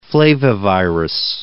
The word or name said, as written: flavivirus